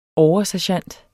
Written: oversergent

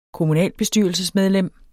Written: kommunalbestyrelsesmedlem

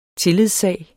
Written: tillidssag

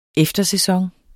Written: eftersæson

